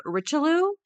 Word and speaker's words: Richelieu: And finally, Cardinal Richelieu, and I did look up that pronunciation. That sounds like it should be incorrect. It is not.